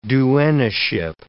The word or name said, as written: duennaship